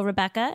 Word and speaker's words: Rebekka: You can find me on social media at Hello Rebecca. And my name is spelled R-E-B-E-K-K-A.